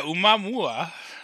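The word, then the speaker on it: Aumuamua (ʻOumuamua): Yeah, it's spelled O-U-M-U-A-M-U-A. Aumuamua. – Type that in Discord. You're obviously not pronouncing it right. – I'm sure I'm not. – Once but... I see a word, we all know I can perfectly pronounce any word as long as I see it. He's typing it currently. Don't know why he didn't just copy and paste. Yeah, Aumuamua. Yeah, you got it right, man.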